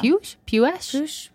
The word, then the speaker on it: Puech: Puech? – Yeah. – P-U-E-C-H.